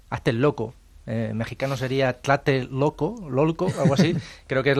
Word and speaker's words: Tlatelolco: Y en esta zona de nombre absolutamente impronunciable como es Tlatelolco. – Tlatelolco.